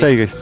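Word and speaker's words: Zeitgeist: ¿Así se pronuncia? Seigeist. – Seigeist. – Sí.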